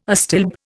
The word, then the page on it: astilbe: Indian